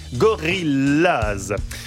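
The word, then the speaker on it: Gorillas: Gorillaz se prononce comme en anglais. Gorillaz.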